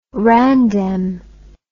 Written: randem

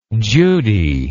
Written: judy

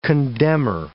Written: condemner

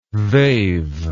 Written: they've

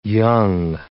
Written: young

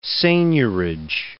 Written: seignorage

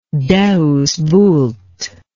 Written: deus vult

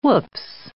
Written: woops

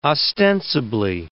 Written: ostensibly

